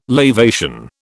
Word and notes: lavation: British English

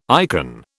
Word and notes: icon: British English

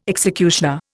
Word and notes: executioner: Indian

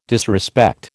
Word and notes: disrespect: American English

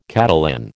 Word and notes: Catalan: American English

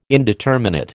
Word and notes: indeterminate: American English

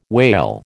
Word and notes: wale: American English

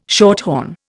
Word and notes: shorthorn: Indian

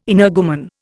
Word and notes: energumen: Indian

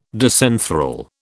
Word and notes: disenthral: British English

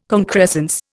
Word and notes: concrescence: Indian

